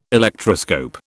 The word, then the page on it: Electroscope: British English